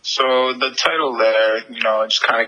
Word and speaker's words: Lerre: So it's spelled L-E-R-R-E. How do you pronounce that? Is it Lara? Lara? How do you pronounce that? – Lair. Silent Lair.